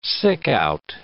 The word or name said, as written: sick-out